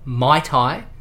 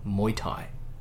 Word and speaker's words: Muay Thai: However, okay, according to the pronunciation on the Wikipedia page about the sport, and a vast majority of professionals that I did come across online, it is pronounced Muay Thai.